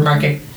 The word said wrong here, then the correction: marquetry: I said that wrong. No, market, marquetry what what is that?